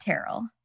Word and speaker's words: Tyrell: but it's spelled like Tyrell. But Dennis said Terrell. – Oh. – But it has a Y, T-Y. – Interesting. – Going to assume Dennis is right.